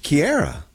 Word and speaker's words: Chiara: We are celebrating a wonderful saint today, a saint that we refer to as Claire, but that's not how her parents pronounced her name, right? Saint Chiara. – Chiara, that's Italian.